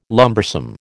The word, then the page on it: lumbersome: American English